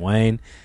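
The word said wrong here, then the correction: Len Wein: is it Wayne or Ween? Len Ween. Len Ween.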